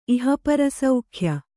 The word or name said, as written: ಇಹಪರಸೌಖ್ಯ